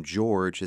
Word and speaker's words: Gyorgy: Her last name, George, is spelled the Hungarian way, G-Y-O-R-G-Y.